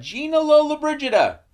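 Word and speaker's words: Gina Lollobrigida: So then Jimmy then shows up with Gina Lola Brigida.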